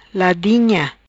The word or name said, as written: Ladinha